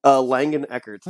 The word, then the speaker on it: Langeneckert: Langeneckert.